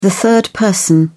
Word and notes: third person: British English

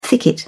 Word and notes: thicket: British English

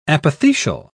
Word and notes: apothecial: British English

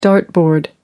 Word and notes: dartboard: American English

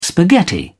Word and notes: spaghetti: British English

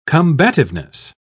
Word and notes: combativeness: British English

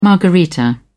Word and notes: margarita: British English

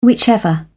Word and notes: whichever: British English

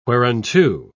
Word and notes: whereunto: British English

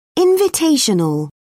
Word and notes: invitational: British English

